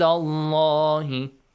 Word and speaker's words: Allah: The pronunciation of the letter lam in Allah is heavily pronounced. And Allah.